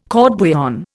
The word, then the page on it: court bouillon: Indian